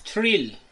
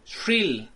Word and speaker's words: Shrill: Thrill. ¿Cuál? – Shrill.